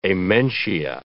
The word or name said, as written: amentia